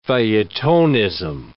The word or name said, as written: feuilletonism